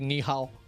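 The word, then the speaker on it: ni hao (你好): Decir ni hao.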